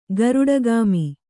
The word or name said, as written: ಗರುಡಗಾಮಿ